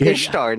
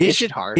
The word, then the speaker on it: Ishtar: You keep fucking mispronouncing that. It's Ishtar, not Ishtar.